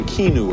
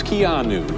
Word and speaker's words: Keanu: for mispronouncing your name. I was saying Keanu or something. It's Keanu.